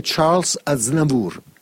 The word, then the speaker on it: Charles Aznavour: E poi un veterano della canzone francese, dimmi se lo pronuncio bene, Charles Aznavour. – Corretto, grazie. Giovedì 22 al O2 World, eccolo qui, Charles Aznavour.